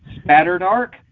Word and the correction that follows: spatterdock: Spatterdark. I can't say it right. Spatterdock. Spatterdock. I was getting tongue-tied on that one.